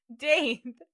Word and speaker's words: Dave: Yeah, when you said you got your gape pierced, I was like, what the fuck is a gape? – Dave. – Am I saying it wrong? – Dave with a D. Yeah, you said gape.